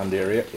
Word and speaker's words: Undaria: For example, the Stala clava, and for example, the Undaria pinnatifida, if I pronounced that right? – Yeah, Undaria, yep.